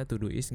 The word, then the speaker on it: Todoist: merencanakan hari-hari yaitu Todoist T O D T O D O I S T jadi bacanya to do is kali ya.